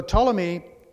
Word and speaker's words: Ptolemy: So Ptolemy, if you wonder how to pronounce that, just the P is silent.